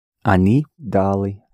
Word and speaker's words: дали: And here the stress is on the first syllable again. – Они дали.